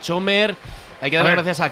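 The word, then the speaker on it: Cömert: Se dice Eray Chömert.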